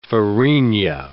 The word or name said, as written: farinha